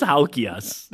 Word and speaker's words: Halkiakis: Halkiakis. I never know how to say the last name. – Stavros Halkiakis. – Oh, okay.